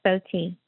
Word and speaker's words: Fotee: Kind of like photo, but it's Fotee.